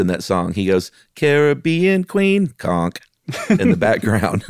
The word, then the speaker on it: Caribbean: It's the Caribbean. Yeah, you have to say it like you're about to sing the Billy Ocean song.